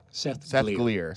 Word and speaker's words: Glier: That would be, it would rhyme with clear. – Seth Glier.